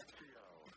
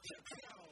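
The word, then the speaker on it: XXIO: Zexio. – Zexio. – Say it with me, Zexio. – Zexio. Well, is it the first time? – No, Zexio has made the list in the past, but it's a really good iron.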